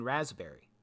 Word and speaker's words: raspberry: There is a P in raspberry.